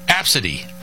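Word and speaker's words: Abcde: Pronounced Absidy. – Absidy. – No. – Absidy. Come on.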